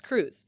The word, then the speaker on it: Crews: And to be clear, your last name is spelled C R E W S. – Exactly, like so the boat the, crew. The Adventure Crew, C R E W S cruise.